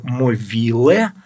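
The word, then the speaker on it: Movilă: Его отец Симеон Могила или Мовиле по румыно-молдавскому произношению, а это более правильное, а Могила – это его стали уже называть так на Украине.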